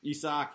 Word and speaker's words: Isak: Isaac. I think I'm saying that right. At Real Sociedad. – Isak, yeah.